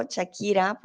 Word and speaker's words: Shakira: Sé que suena con Che, pero Shakira por alguna razón se escribe con S, ¿vale? Escribe así, Shakira, pero lo decimos como si fuera una Che.